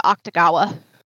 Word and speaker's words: Akutagawa: Akutagawa? – I think they pronounce it Octagawa. – Yes, Octagawa.